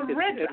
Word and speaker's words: Ritz: It's pronounced like Ritz, like the cracker. – Oh, Ritz. – Edward Ritz. – Yeah. Okay. – R-I-T-Z.